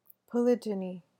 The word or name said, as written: polygyny